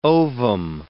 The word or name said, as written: ovum